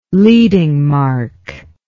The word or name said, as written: leading mark